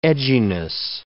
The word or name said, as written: edginess